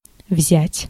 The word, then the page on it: взять: [vzʲa'tʲ]; /vzʲætʲ/